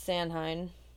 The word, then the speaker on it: Samhain: I always thought it was Samhain, but apparently it's pronounced. – I thought it uh, was Samhain. – Uh, no, it's Samhain. – Isn't it Samhain? – No. – Are you sure? – I'm positive.